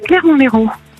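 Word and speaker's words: Clermont-l'Hérault: Clermont-Léraud. – Dans les roues. Bon, voilà. Comme ça se dit et ça se prononce.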